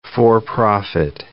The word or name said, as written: for-profit